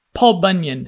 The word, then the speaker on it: Paul Bunyan: Not just gods, also mythological heroes like Paul Albanian. – Paul Bunyan. – Paul Bunyan. – My wife is Bulgarian, so she doesn't quite know. She has just learned the proper pronunciation of Paul Bunyan.